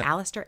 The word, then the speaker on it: Aleister: Crowley adopted the name Alistair. I hope I'm pronouncing that right. A-L-E-I-S-T-E-R. – Sure, yeah. – Alistair, A-Lister.